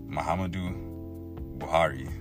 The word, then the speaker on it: Muhammadu Buhari: Muhammadu Buhari.